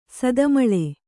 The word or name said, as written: ಸದಮಲ